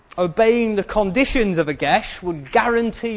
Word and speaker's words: geas: It's called, and I apologise in advance if I mispronounce this, it's called Gesh. It's spelled G-E-A-S, and apparently it's something like Gesh, although it varies depending on where in Ireland you might be from.